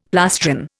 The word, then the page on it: plastron: Indian